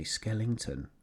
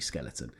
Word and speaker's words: Skeleton: I say Skellington, not Skeleton. No, okay. It is actually Skeleton.